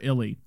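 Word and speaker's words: Ehle: is her and Jennifer L. Is that how you pronounce her name? Look at that. Jennifer Illy.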